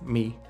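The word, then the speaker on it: mi (米): Now rice in Chinese is pronounced mi, and rabbit is pronounced tu.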